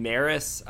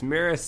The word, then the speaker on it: Maris: So is the girl's name Maris? I want to make sure I say it right. Is it Maris? Is how you say it? M-A-R-I-S, the one that he was, I guess, the daughter of Lord Boros, I guess is his name, right? – Yes, Lord Boros, but I believe Maris is the name of the daughter. I think that's how it's pronounced, yes.